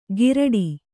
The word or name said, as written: ಘಿರಟಿ